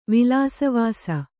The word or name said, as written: ವಿಲಾಸವಾಸ